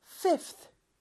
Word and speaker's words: fifth: 다섯 번째는 five 다음에 th인데 아까 twelfth처럼 v는 f로 변한다고 했죠. 발음 때문에. 그래서 fifth, fifth가 됩니다.